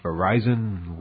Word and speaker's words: Verizon: The joint venture, to be known as Verizon Wireless, will be the first entity to use the new name. The word Verizon, pronounced the way I'm saying it, according to them, was selected from more than 8,500 names.